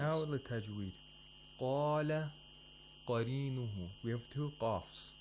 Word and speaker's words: قَالَ: Qaf is always pronounced with tafkhim because it's a letter of istirla. So, قَالَ. Only the qaf is pronounced with tafkhim.